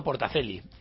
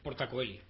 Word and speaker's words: Portacoeli: Pero que a la hora de pronunciar... – Portacoeli, porque es la forma original en latín.